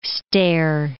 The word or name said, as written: stair